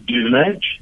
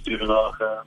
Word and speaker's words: Duvenage: You know, in this country, it doesn't really matter because there are many ways to spell it, but it's pronounced Duvenage. That's my official word. When you add an H, it's Duvenage. The French call it Duvenage. It doesn't really matter.